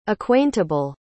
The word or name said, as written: acquaintable